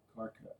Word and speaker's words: Karkut: Is it Karkut? – Karkut. Pretend like it's C's but K's. – Cool. Karkut.